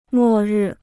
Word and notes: 末日: mò rì

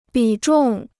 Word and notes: 比重: bǐ zhòng

